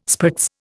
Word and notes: spritz: Indian

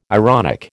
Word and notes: ironic: American English

